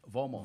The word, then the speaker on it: Vomo: Vomo.